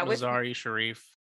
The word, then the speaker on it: Mazar-e-Sharif: I don't know how many flights, but the planes were grounded and stuck at the Mazar-e-Sharif. Anyone know how to say that properly? Airport. – It's pretty close. Yeah, Mazar-e-Sharif.